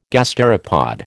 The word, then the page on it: gasteropod: American English